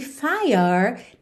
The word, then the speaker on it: fire: Aqui atenção que fire não tem o E pronunciado, certo? Você termina no som do R.